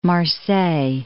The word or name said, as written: Marseille